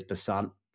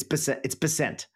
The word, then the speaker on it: Bessent: And apparently it is Bessent, like percent or Bessent. Maybe it's, I've already forgotten what it is. Is it percent? It's Bessent.